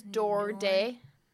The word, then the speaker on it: Doherty: It's we give it two syllables. Doherty, like Doris Day.